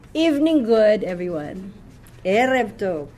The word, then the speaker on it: Erev: Erev. Okay, remember the accent is on the first syllable, all right? This is Erev.